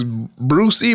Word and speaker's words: Bruxie: What it was, Brucey, but it's spelled Bruxie.